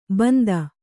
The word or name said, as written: ಬಿಂದು